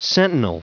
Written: sentinel

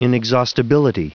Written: inexhaustibility

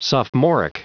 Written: sophomoric

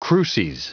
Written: cruces

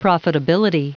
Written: profitability

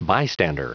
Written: bystander

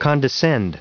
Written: condescend

